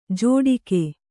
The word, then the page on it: ಜೋಡಿಕೆ: jōḍike